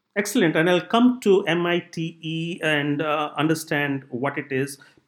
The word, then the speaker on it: MITE: M-I-T-E, but we pronounce it MITE.